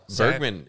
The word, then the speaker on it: Bergman: Do you think that's how you pronounce her name? – Bergman. Bergman sounds right. Sandahl. – Bergman is right.